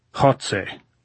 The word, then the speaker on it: Goltz: How do you say Gold in Spanish, Comrade General? – Jotze, said Goltz, grinning, making the sound deep in his throat as though hawking with a bad cold.